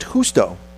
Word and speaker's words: Justo: This is Gusto Arroa, I think it's pronounced Justo. – Justo. – Justo? – Justo. – Or at the very least, if you want to go completely Anglo on it, you say Justo, but you can't change a J to a G.